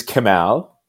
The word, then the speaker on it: Kemal: First name is Kemal. Last name is Kılıçdaroğlu. Kılıçdaroğlu. – It's not that hard.